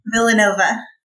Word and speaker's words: Villanova: After attending immigration law courses at Villanova, how do you say the university? Villanova. There it is.